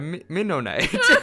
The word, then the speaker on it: Mennonite: Yeah, a Mennonite. I said it wrong, right? – Yeah. – Oh, it's a Mennonite, eh? – Mennonite.